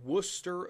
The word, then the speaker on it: Wooster: So you have these Virginia and Pennsylvania militias whose ultimate goal is to meet at a place called Worcester, Ohio. It's not spelled like Worcestershire sauce, folks. Worcester, Ohio, spelled W-O-O-S-T-E-R.